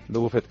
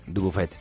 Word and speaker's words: Dubuffet: Dubuffet. – Dubuffet.